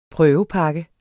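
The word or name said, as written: prøvepakke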